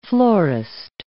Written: florist

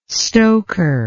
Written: stoker

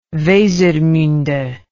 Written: wesermunde

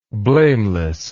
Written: blamelessness

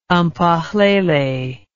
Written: Mphahlele

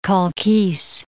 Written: Khalkis

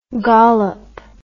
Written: gollop

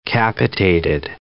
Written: capitated

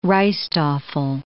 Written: rijsttafel

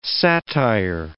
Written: satire

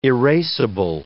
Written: erasable

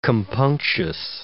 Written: compunctious

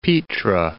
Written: Petra

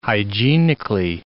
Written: hygienically

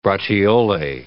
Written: braciole

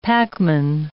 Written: packman

